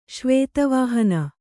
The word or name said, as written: ಶ್ವೇತವಾಹನ